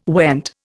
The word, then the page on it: went: Indian